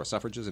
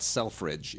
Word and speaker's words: Selfridge: You pronounce it Suffrage. It's not Selfridge. It's Suffrage, Mr. Suffrage.